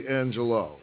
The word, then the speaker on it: Angelou: Is her name pronounced Angelou or Angelou? – It's actually Angelou. – Angelou, okay. – Right, I heard someone make the correction this morning. Because I all this time have been saying Angelou.